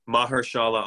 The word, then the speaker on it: Mahershala: Mark Marshala Ali will play Blade in the MCU. Is that how you say his name? Mahershala Ali. Mahershala Ali.